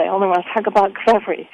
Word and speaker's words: kvevri: And in Georgia, they have amphora-shaped vessels that they call kvevri. That's like K-V-E-V-R-I, kvevri.